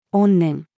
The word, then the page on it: 怨念: female